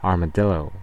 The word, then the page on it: armadillo: American English; GenAm; /ɑɹməˈdɪloʊ/